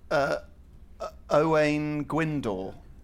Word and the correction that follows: Owain Glyndŵr: Ah. it's, it's Owain Glyndŵr.